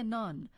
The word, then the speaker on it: نان: یا کلمه نان که در کابل به این صورت تلفظ می شود و در ایران نون گفته می شود.